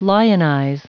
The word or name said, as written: lionize